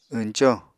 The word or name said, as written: wñco